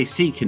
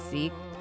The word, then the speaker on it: Sikh: I'm sure you're all right for America, but like people say Sikh and people say Sikh. In the English speaking world, it's probably more widely said as Sikh, just to not confuse it with the word sick or ill, you know. Yeah. But in Punjabi, if you read the actual way it's written, it's pronounced in Punjabi, it's sick.